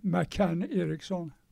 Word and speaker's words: McCann Erickson: McCann Erickson, med amerikanskt uttal.